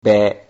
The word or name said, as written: be